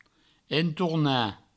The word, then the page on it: entornar: entourna; provençal